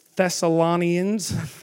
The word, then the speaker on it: Thessalonians: 1 Thessalonians, if I'm saying that right. Thessalonians. Okay, we're good.